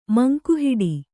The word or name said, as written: ಮಂಕುಹಿಡಿ